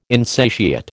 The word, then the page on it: insatiate: American English